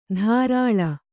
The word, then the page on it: ಧಾರಾಳ: dhārāḷa